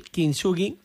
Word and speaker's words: kintsugi: En la conferencia que vas a ofrecer el viernes se hablará del arte japonés del kintsugi, que no sé si se pronuncia así exactamente, sí.